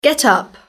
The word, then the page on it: get up: British English